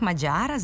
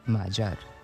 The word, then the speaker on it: Mađar: Isto bi trebalo da bude i s prezimenom Mađar, ali...